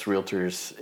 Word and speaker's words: realtor: I mean, I think most of us realtors in the area love to. – Let's take a time out there. He's a realtor.